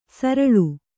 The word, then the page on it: ಸರಲ್: saral